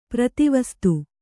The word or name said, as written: ಪ್ರತಿವಸ್ತು